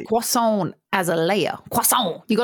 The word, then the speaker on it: croissant: Because I guess like other layered foods, like croissants are technically just like millions of layers of like doughy bread, right?